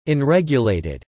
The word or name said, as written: unregulated